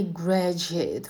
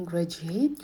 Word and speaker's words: graduate: И graduate в произношении ударение падает на первый слог. Не graduate, не уходите дальше, а graduate.